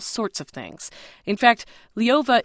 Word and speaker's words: Ljova: Not in the hands of Liova. That's spelled L-J-O-V-A. Liova.